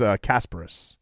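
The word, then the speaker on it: Kasparas: Did I say that correctly? – Kind of. – Okay. – You got the last name pretty good. It's Kasperis.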